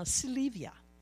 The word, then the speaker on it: Sylvia: Just like when I go to Uganda, my name is no longer Sylvia, it is now Silivia. Because they pronounce my name the way they would pronounce it.